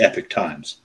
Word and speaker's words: Epoch Times: Yeah, it's spelled like Epoch, but they call it the Epic Times.